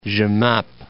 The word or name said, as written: Jemappes